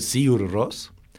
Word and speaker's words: Sigur Rós: No se pronuncia la G. Esto es un dato interesante. Se dice Sigur Rós.